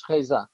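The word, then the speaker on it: Reza: Is that the correct pronunciation of his last name, Riza? – I would think so. Yes, Riza.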